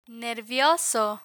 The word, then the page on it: nervioso: Spanish